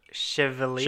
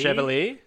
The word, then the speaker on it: Chevalier: Is it Chev? How do you say that? – Chevalier. – Chevalier. – Yeah. – Like Chevalier. That's what I thought.